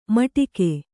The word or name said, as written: ಮಟಿಕೆ